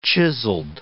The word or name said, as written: chiseled